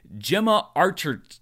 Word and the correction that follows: Gemma Arterton: Okay, I'm going to get this one of these days. Gemma Arterton.